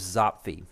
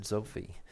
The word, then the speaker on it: Zopfi: youth soccer chairman, who's a guy named Pete, I believe he told me it was Zofi. I was thinking it was Zopfi. It's spelled Z-O-P-F-I, but I believe he said Zofi.